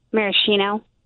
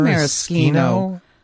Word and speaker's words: maraschino: No, it isn't, because this S-C-H bruschetta is pronounced with a K sound. Why wouldn't it be maraschino? – No one's.